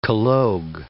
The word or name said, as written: collogue